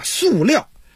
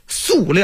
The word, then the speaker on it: 塑: 说到这儿啊，我再录一句啊，说刚才这个塑料布啊，这个塑料这是北京话啊，正确读音咱得说念塑，那塑料。现在的咱们的中学生用的新华字典已经是这么改了，塑料啊，尤其咱们学生考试的时候千万别错了，我再突出一遍啊，叫塑料。